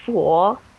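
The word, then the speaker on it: Fo (佛): Well, in China, the Buddha is called the Fo, F-O.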